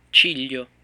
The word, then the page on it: ciglio: Italian